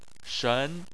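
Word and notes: shen: tone 2

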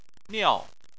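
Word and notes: niao: tone 4

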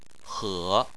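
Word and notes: he: tone 3

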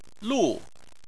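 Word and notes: lu: tone 4

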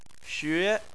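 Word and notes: xue: tone 2

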